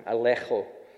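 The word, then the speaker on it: elegcho: Now the Greek word for rebuke here is alecho. And I learned how to say it right this time, alecho.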